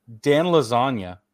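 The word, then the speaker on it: Iassogna: And Dan Lasagna, that's how you say his name.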